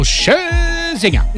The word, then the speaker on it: Scherzinger: Now, I don't know if you heard this story, Nikki, but the former Pussycat Doll and Australia's Got Talent judge, Nicole Scherzinger. – Yep. – Which is, I believe, the correct way to pronounce her name.